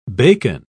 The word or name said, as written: bacon